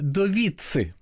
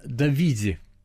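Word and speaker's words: Dovizi: На Руси все неправильно произносят его имя – Довицы. Забудьте это произношение. Правильно, Давиди.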